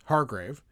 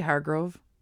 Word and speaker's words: Hargrave: I thought that this game was Elizabeth Hargrave. – Did I say Hargrove? I thought I said Hargrove. I might have said it wrong.